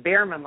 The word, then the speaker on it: Bierman: Am I pronouncing your last name correctly? – You're almost there. It's Bierman, like Smokey the Bear.